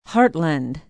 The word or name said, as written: hartland